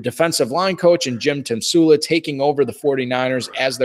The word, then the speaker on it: Tomsula: Tomsula. I believe that's how you pronounce it. Tomsula? Tomsula.